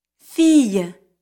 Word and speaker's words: fille: I-L-L, I-L. These combinations are pronounced like the Y in the English word year. – Fille, soleil.